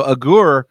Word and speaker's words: Agur: But you're right, chapter 30 is written by this guy, A-G-U-R, and it's so interesting that no one knows even how to, he's so obscure, Agur, A-G-U-R, is so obscure, no one knows how to pronounce it. So I rhyme it with Agur, the prayer of Agur, but I think I've heard it the way you pronounce it there as well.